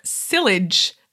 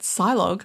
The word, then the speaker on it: sillage: It is actually sillage, because she has sillage, rhymes with village, is our newsletter. So ignore my ignorance at Silog, which is what I've been calling it.